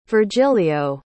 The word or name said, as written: virgilio